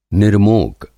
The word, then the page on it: निर्मोक: Hindi